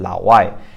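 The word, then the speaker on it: lao wai (老外): It basically means, you can think of it as meaning basically foreigner, and it's pronounced third tone, fourth tone, lao wai.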